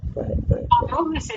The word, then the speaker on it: Tammuz: Tammuz in English, Tamuz, like zebra.